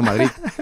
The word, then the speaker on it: Madrid: Aquí siempre hemos dicho Madrid, porque tiene una D final, ni T ni Z, pero bueno, claro, ellos lo pronuncian como quieren, porque han nacido en Madrid.